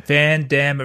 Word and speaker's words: Van Damme: Van Damme, it rhymes, wham, bam, thank you, ma'am.